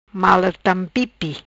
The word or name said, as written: Mala tampipi